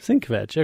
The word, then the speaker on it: Sienkiewicz: It's definitely not Bill Sienkiewicz. Am I saying that right? – Sienkiewicz.